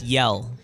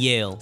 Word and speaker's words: Yale: No, that's yell. Grito University. Yell and Yale.